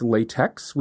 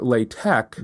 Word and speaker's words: LaTeX: I have heard it pronounced LaTeX, which is how it's spelled. – Gross. – And you're pronouncing it LaTeX. – Right. I'm right. – Okay. Is this a GIF thing? – No. This is everyone who uses tech calls it tech, and then LaTeX follows up from tech. Although there were some. – And that was also spelled T-E-X.